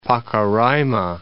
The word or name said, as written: Pacaraima Mountains